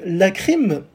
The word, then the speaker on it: La Crime: Et donc, euh, la crime, alors ça s'écrit L-A, comme l'article là, et crime en anglais, la crème, quoi.